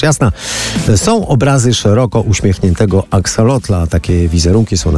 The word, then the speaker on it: axolotl: W Mexico City wszędzie widać wizerunki axolotla, a właściwie axolotla, bo tak się to wymawia, czyli ambystomy lub też salamandry meksykańskiej.